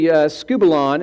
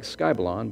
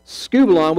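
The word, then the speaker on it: skubalon (σκύβαλον): It's actually skubalon is how it's pronounced. I know it looks like skybalon, but it's skubalon, which means basically dung.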